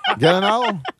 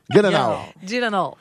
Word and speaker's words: Gyllenhaal: Jake Gyllenhaal. – Gyllenhaal. – Gyllenhaal. – Moi, je dis un G, vous autres, dites-vous un Guy.